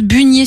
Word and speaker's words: beunier: Le verbe, c'est beunier et on prononce bunier sur Bordeaux. – Ok. – Donc bon, écoute, on va la croire. Et là, par exemple... – Moi, j'ai toujours dit bignier, mais tu sais quoi? – Tu vois, Maëva de Bordeaux, elle dit bunier.